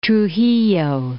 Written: Trujillo

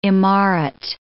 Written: imaret

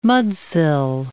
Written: mudsill

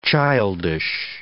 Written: childish

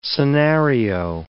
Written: scenario

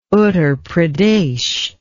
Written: Uttar Pradesh

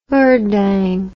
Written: Urdang

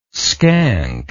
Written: skank

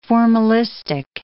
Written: formalistic

